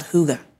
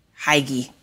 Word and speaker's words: hygge: There's a, in Danish culture, this thing called hygge. It's spelled H-Y-G-G-E. Looks like hygge. – Okay. – But it's hygge.